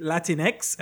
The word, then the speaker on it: Latinx: You're a, and I think you just pronounce it Latinx. They just put an X instead of the O or the A.